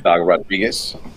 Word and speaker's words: Dago Rodriguez: My name is Dago Rodriguez,